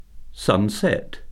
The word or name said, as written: sunset